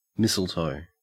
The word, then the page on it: mistletoe: Australian English